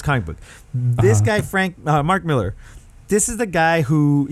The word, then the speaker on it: Millar: But it's made by Mark Miller, whose name is spelled with an A R, and I've been calling him Mark Millar for about 15 years. Nope, his name is Miller.